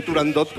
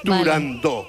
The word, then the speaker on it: Turandot: pues yo también lo digo mal y digo Turandot como todo el mundo. Turandot. Pero para que quede claro... – Entonces todos tontos ya. – Para que quede claro, es Turandot.